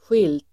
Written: skilt

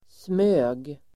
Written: smög

